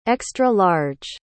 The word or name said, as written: extra-large